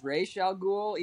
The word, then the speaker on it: Ra's al Ghul: Yeah, technically the correct pronunciation is Ra's al Ghul, even though it doesn't make any sense. – Ra's al Ghul. – They don't pronounce like that in the movie, but like it is technically pronounced.